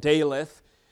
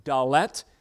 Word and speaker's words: Daleth: That would be, I'm going to get this pronunciation wrong because the reader of my Bible pronounced it Daleth, but one of the Hebrew scholars here in our midst, Jaden, tells me it's pronounced Daleth.